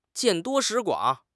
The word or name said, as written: 见多识广